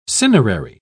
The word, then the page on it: cinerary: British English